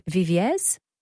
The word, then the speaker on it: Viviers: You can discover what it's like to savour Lyon's world-famous cuisine, experience Paris's timeless charm, explore the picturesque riverside villages of Viviers.